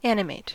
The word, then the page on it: animate: American English